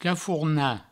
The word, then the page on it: cafornar: cafourna